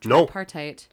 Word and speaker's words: Tripartite: Japan joins Mussolini and Hitler, the Tripartite Act. Did I just absolutely butcher that? – Tripartite. No, I think you nailed that, actually.